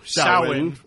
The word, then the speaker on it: Samhain: And on the blackboard is written in Michael's own blood, Samhain, or Samhain, proper pronunciation.